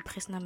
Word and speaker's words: PRSNA: J'ai trouvé un stage en tant que journaliste mode et culture euh, chez Presna Magazine. Donc ça s'écrit P-R-S-N-A Magazine. Donc vous pouvez taper sur Instagram, ou même sur Google pour tomber sur le site.